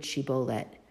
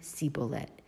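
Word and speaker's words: shibboleth: He said, say the word shibolet. Vayomer sibolet. But the people from Ephraim, and here you could see that there's different dialects among the different tribes, the people from Ephraim can't pronounce that shin sound. So instead they say the word sibolet.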